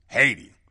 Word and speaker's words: Haiti: That's how you pronounce Haiti. Haiti. No, it's Haiti.